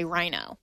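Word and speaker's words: RINO: rhino, which is, of course, the term for Republican in name only. It's not the R-H-I-N-O. Right. There is no H. The H is silent in this acronym.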